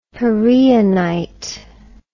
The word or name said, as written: pereonite